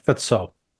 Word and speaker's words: Fico: It's spelled Fico, but it's pronounced like Fitzo.